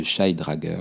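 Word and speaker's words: Shy-Drager: Il faut éliminer dans les trous ce qu'on appelle le syndrome de Scheidrager, donc ça s'écrit S-H-Y-Drager, donc D-R-A-G-E-R.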